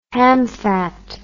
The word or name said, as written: hamfat